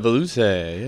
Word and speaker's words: velouté: When the chorizo, how do you say it? Velouté? – The velouté, yeah.